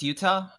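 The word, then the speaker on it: Jutah: I want to say real quick that name is spelled with a J. The J is silent, but it is not... It looks like the state, but... – Yeah. – Don't forget the J. – It's pronounced Yuta, but I think that's just because in Japanese it would also kind of sound mostly the same.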